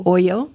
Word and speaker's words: OYO: And I think it's pronounced Oyo. – Oyo. – O-Y-O.